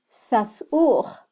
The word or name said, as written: sasuj